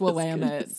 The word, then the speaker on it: Willamette: The Willamette Valley. – It's Willamette.